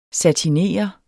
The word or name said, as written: satinere